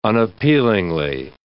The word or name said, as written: unappealingly